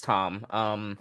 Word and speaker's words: Thom: I thought it was Tom, but it's spelled with a TH. So I don't know if it's Tom or Thom. – Yeah, it's Tom.